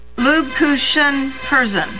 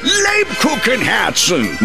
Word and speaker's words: Lebkuchenherzen: Lubkuchen Herzen. – The Kupenhergens Herzen. – Lubkuchen Herzen. – Nein! Lebkuchenherzen! No matter how you pronounce it, it can only mean one thing.